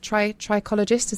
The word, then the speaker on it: trichologist: one of the UK's leading trichologists from the award-winning Wimpole Clinic. Did I say that right? Is it trichologist? Is that correct? – You did. Yes, it's a mouthful, but yes, it's trichologist.